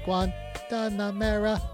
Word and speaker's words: Guantanamera: No. It's Guantanamera. What do you say it is? – Well, he keeps on saying it. Guantanamera. – Guantanamera. – There you go. It's like that extra syllable.